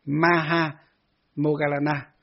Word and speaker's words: Maha Moggallana: Đức Phật thường tán dương khen ngợi và nói trước đại chúng là tôn giả Maha Moggallana, đọc đúng là Maha Moggallana, tức là tôn giả Maha Mục Kiền Liên.